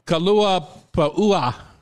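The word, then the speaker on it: kalua pa'ua (kalua puaʻa): Mango cabbage slaw, true Hawaiian macaroni salad, kalua pa'ua. You have to pronounce all the vowels.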